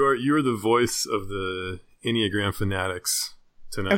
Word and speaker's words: Enneagram: I know nothing about the Enneagram. Is that how you say it? Like, I literally don't know. – Yes, that's how you pronounce it.